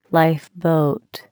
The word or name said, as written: lifeboat